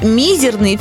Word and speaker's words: мизерный: Однако словарь русского словесного ударения Зарвы рекомендует произносить прилагательное только с ударением на «и». Поэтому произносить «мизерный» все же более правильно.